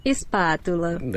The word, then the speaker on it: espátula: This is how you say spatula in Portuguese. – Espátula.